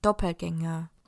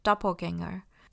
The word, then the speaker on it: Doppelgänger: Doppelganger，这个是德文 Doppelganger，那英文的话呢，比较常念成 Doppelganger。